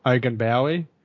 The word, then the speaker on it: Ogunbowale: Ogunbowale, right.